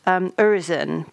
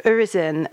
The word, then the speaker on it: Urizen: There's a wonderful anecdote about St. Paul's Cathedral that you relate in the book, where one of Blake's pantheon, I want to pronounce it Urizen, because it sounds a little like horizon and that sense of kind of limitation,